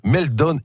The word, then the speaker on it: Meltdown: Alors, c'est, je ne sais pas si je prononce bien, Meltdown et Spectre. – Oui, c'est pas mal prononcé.